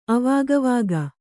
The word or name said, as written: ಅವಾಗವಾಗ